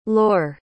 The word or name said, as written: lor